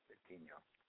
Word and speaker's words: Betinho: I think the H is kind of like the Y sound, Betinho.